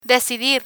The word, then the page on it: decidir: spanish